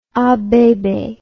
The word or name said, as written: abebe